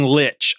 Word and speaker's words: lich: And yeah, I'm saying lich. I know that there's a heated debate out there on how to pronounce that word, L-I-C-H. And I'm not trying to start anything, but that's how I pronounce it. And in fact, I watched a whole video yesterday on how to pronounce the word correctly. And in the end, yeah, lich. Don't at me. Which I said earlier, I wasn't trying to say, but now I am on this one particular topic.